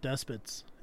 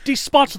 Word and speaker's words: despot: I looked it up, and the official, like, correct pronunciation listed is supposed to be despot.